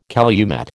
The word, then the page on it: calumet: American English